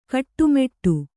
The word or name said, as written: ಗಟ್ಟಿಮುಟ್ಟು